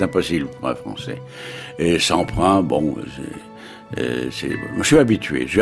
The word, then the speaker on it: Semprun: Nous allons prononcer à la française le nom de Semprin, c'est plus sûr.